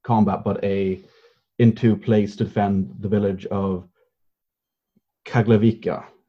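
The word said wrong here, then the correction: Čaglavica: if I'm pronouncing that right. – Basically, it was Chaglavica, but there were many other villages also.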